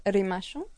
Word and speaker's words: rimashu: No tenemos una palabra específica como para chao, adiós, pero decimos rimashu.